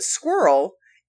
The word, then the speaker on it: squirrel: Some suggest that squirreled could be added, but squirrel is intended to be pronounced with two syllables, squirrel, according to most dictionaries.